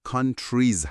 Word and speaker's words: countries: countries تلفظش countries هست، یعنی اون آخرش صدای ایز میده. countries.